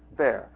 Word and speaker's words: Baer: I have to ask one of the Germans how one properly pronounces this. Bear. Okay, so I've always pronounced it correctly.